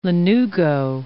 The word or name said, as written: lanugo